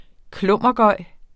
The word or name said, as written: klummergøj